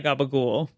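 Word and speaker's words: capicola: Which I'm pretty sure is, I think it's supposed to be Capricola. – Yes. – But it ends up being pronounced, and it sounds like gabagool.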